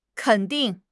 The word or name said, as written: ken3 ding4